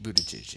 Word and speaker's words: Buttigieg: Is Buttigieg how you say his name? – Yeah. Yeah. Pete Buttigieg, yeah.